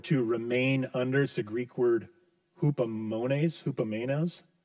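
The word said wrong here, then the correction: Hupomone (ὑπομονή): I don't know if I'm saying that right. But it's this staying under pressure. Staying under pressure. What was it? Hupomone. There we go.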